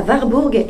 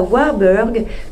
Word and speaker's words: Warburg: « écoute, je recommande que dans ton speech, tu évoques la conférence d'Abby Warburg, que tu prononceras Warburg et pas Warburg, comme le font les Anglais.